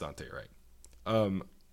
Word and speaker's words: Daunte Wright: I hope I'm pronouncing that correctly. There's a U in there that really throws me off. I think it's pronounced Daunte Wright.